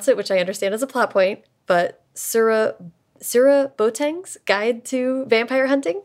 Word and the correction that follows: Serwa Boateng: So it's pronounced Serwa Boateng's Guide to Vampire Hunting. Serwa, yeah, it's almost like the English name Sarah, but Wa kind of. Serwa.